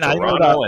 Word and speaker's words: Toronto: That is not how you pronounce it. There is one T in Toronto, and it's barely a T. It's even Toronto, and that's it.